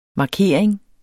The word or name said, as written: markering